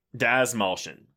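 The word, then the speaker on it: Dastmalchian: Like a 1930s David Desmalchian. What? God damn it. I'm never going to get his name right, but you know what I'm talking about. – Desmalchian.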